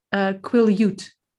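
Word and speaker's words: Quileute: But the Quillette, I read this word more than I try to say it. – It's pronounced Quillute.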